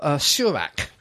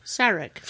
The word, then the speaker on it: Sarek: to Surak. – Sarek.